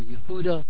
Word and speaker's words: Yehuda: You know how the word is pronounced in Hebrew, Yehuda.